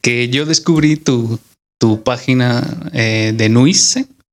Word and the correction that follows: Nui Cerámicas: ¿Cómo se, ¿Cómo se pronuncia? – Es Nui Cerámicas. – Nui Cerámica. Ok.